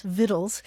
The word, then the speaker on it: victuals: So today you'll see either the word vittle spelled V-I-T-T-L-E or V-I-T-T-L-E-S, vittles, and you'll also see it spelled V-I-C-T-U-A-L-S, vittles. And you look it up in the dictionary and the pronunciation is vittles for both words.